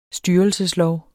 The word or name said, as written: STYRELSESLOV